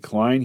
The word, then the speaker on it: Klein: It's where, by the way, I learned how to pronounce his name, because it says Vincent Klein, say Klein.